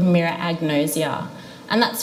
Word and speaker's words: agnosia: Agnosia, A-G-N-O-S-I-A, agnosia, A-G-N-O-S-I-A.